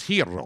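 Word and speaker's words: gyro: It's pronounced hero. – Hero. – But it's G-Y. – Because it's Greek. – It is Greek. And in Greece, the G is not pronounced a G.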